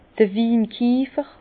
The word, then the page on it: d'r Winkiefer: Alsatian; Bas-Rhin; Herrlisheim